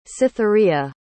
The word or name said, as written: cytherea